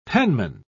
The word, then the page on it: penman: British English